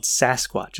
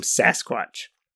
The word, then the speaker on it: Sasquatch: And their original term was more like Sesquets or Sescots. And we, like people like me that can't pronounce things correctly, it became Sasquatch.